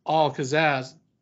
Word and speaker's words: Al-Kazzaz: And we have returning guest, podcast host himself, the Bear of Texas, Alex Al-Kazzaz.